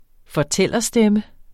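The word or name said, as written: fortællerstemme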